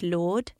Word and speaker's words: Lourdes: So L-O-U-R-D-E-S. And again, the S is silent. But I don't think she minds a bit of a complicated name in that way. I really don't think that this couple mind that. The S is silent. Lourdes, I think it's really nicely. And you can even say it just Lord. – Yeah, which is how I like it. – Which is one syllable.